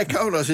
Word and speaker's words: Kaunas: In Kaunas, zeg ik dat goed? Is het Kaunas? – Nou, het is wel koud in Nas. – Ja, Kaunas in Litouwen.